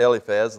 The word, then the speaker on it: Eliphaz: And I pronounce that Eliphaz, I think that's how John pronounces it. I have seen pronunciations where it's Eliphaz, but it seems like for the most part, it's the spelling pronunciations that you see and hear are Eliphaz, so I'll go with that.